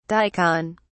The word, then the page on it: daikon: British English